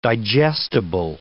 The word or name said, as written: digestible